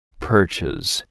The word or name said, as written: Perches